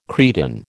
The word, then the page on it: cretin: American English